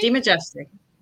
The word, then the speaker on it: DeMajistre: DeMajistre.